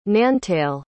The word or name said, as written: nantale